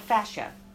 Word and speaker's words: fascia: And that is called fascia. Oh. Fascia. That's a recently new discovery about the human body. It's spelled F-A-S-C-H-I-A.